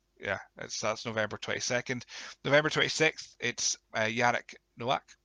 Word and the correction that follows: Nowak: Novak. I said it wrong so many times, but Jarek Novak that we'll be speaking to.